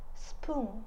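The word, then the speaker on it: spoon (スプーン): 那勺子呢是 spoon spoon spoon 啊，这个发音有一点点的微妙，我们克服一下啊。 spoon spoon 啊，那我要勺子就是 spoon ください。 – spoon ください。 spoon ください。 spoon ください。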